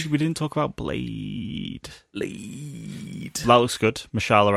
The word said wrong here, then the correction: Mahershala: Ali. Mahershala Ali. – Yeah. Mahershala. Um, yeah.